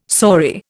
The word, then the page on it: saury: Indian